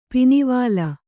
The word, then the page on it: ಪಿನಿವಾಲ: pinivāla